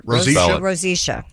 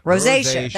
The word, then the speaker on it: rosacea: Rosacea, yes. – Oh. Rosacea.